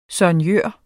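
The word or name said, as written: SOIGNØR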